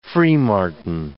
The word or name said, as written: freemartin